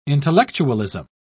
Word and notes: intellectualism: British English

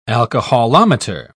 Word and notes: alcoholometer: British English

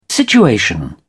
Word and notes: situation: British English

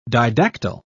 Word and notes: didactyl: British English

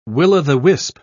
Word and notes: will-o'-the-wisp: British English

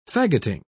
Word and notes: fagoting: British English

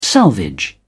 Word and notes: salvage: British English

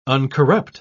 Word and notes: uncorrupt: British English

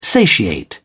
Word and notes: satiate: British English

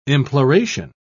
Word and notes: imploration: British English

